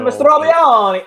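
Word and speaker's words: Mastrobuoni: And then maybe you have some depth with acquisition from the Rays and Mastrobioni. I don't know if I'm pronouncing that right. – Yeah, Mastrobioni.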